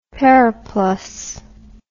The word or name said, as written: periplus